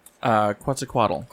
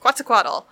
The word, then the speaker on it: Quetzalcoatl: Quetzalcoatl. – Quetzalcoatl.